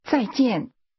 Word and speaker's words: 再见: That's pronounced with two falling tones. Say goodbye. – 再见。